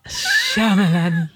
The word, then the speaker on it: Shyamalan: Shyamalan. I've been mispronouncing it forever.